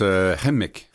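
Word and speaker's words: Kukum: Est-ce que je prononce bien? – En fait, quoi? on prononce Hemik. – Hemik. – Oh. C'est Elisabeth Isaac qui me dit, c'est comme des Arabes. Hemik. – Hemik. – Ils mettent l'accent, c'est Hemik et non pas Hemik.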